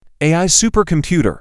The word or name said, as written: AI supercomputer